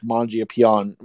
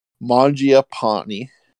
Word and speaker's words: Mangiapane: Surprising leaps forward by guys like Mangiapane. Oh, I just butchered that. Mangiapane. There we go.